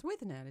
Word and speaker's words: Withnail: You say Whitnail, don't you? Do you say Withnail or Whitnail? – It's Withnail, isn't it? – Oh, I think I've heard people pronounce it.